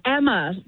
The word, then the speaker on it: Emma: Daughter, it's Emma. Yeah, pronounced like hammer, but no H.